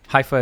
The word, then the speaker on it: Haifa: Well, am I pronouncing it right? Is it Haifa, Israel? – Yeah, Haifa, Israel.